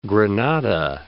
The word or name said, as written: Granada